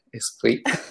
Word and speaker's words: esprit: Innanzitutto complimenti per la pronuncia francese, esprit.